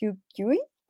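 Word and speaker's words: Yuk Hui: Em, perdón, no, soy así experta en su nombre, pero es Hugh Huey. si lo dije bien. – Sí, Hugh sí, sí.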